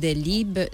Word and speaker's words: Delibes: tú dices Delib Delib, que se escribe Delibes como eh, nuestro escritor efectivamente con B con todo